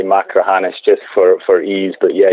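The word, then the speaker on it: Machrihanish: I got schooled pretty good the other day talking to David McClay Kidd about Macrahanish, and he made me pronounce it over and over and over again to really get the back of the throat Macrahanish going.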